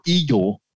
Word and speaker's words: iGel: And then what you're seeing back over here is my iGel, or Eagle, as it's pronounced in German, my iGel lab.